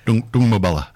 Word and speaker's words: Doombabula: So these things are, and that's putting at risk things like the, Doombabula Springs. Anyway, there's springs. – Oh, Doombabula. – That's it. Thank you. Yes, I knew I wouldn't get the pronunciation right.